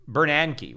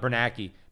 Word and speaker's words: Bernanke: Yeah, it's Bernanke, right? Not Bernanke. Ben Bernanke.